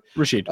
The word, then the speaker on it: Rashid: Rashid Shaheed, not sure how that first name's pronounced Rashid. actually. – Rashid.